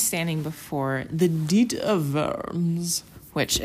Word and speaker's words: Worms: And they're like, no, it's pronounced Diet of Worms. And I'm like, ugh, German.